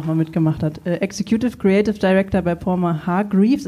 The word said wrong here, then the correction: Palmer Hargreaves: ist das richtig ausgesprochen? – Ja, fast. – Ja. Ähm, nah genug dran. Ich glaube, es heißt Palmer Hargraves.